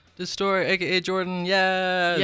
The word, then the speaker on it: DI$TXRT: Yeah, well, it's DI$TXRT. I know it's confusing. Yeah, it is. But it's pronounced Destroy.